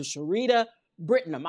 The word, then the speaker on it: Charita Brittenum: Please, ladies and gentlemen, give a round of applause to Charita Brittenum.